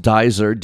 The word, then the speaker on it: Duyser: A woman named Diane, I believe it's pronounced Dyser, D-U-Y-S-E-R, was about to bite into her grilled cheese sandwich.